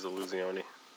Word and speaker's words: Illusione: And is that how you say it? It's not Illusione, it's Illusione? – I believe it is Illusione.